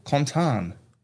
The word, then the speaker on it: Quentin: And in the French, it's Quentin. That's actually how it's said, Quentin, like that.